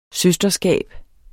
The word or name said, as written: søsterskab